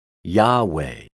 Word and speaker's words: Yahweh: This is referred to as the Tetragrammaton, and in English is generally spelled YAHWEH, say Yahweh. However, when I was a very young boy, my grandfather, who grew up in Syria speaking Aramaic, taught me to pronounce the Father's name by saying Yahweh.